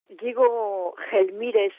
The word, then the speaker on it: Diego Gelmírez: Diego Gelmírez, je vais le prononcer à l'espagnol, est un archevêque de la cathédrale de Saint-Jacques de Compostelle.